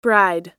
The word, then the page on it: bride: American English